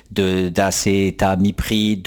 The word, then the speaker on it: acétamipride: les grosses productions agricoles avec toutes les saloperies d'acétamipride, de cadmium. – Tu l'as dit sans l'écorcher, bravo.